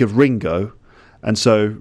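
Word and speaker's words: ringo (りんご): For example, the Japanese word for apple is ringo, ringo. Okay, I always remember this. I always remember the Japanese word for apple. In my pronunciation it's Ringo, but in Japanese it would be like Ringo.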